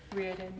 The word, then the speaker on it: Riordan: I never know how to say his name. – Riordan, yeah.